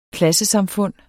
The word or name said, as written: klassesamfund